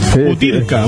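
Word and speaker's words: Butyrka: ¿Cómo se pronuncia? Butrisca. – Sí, sí, oh. Viste que meten unas letras. Butirca. – Butirca, ahí está.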